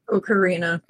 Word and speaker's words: Ocarina: I think, what is it? The Arcana of Time? – Ocarina.